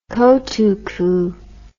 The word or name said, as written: kotuku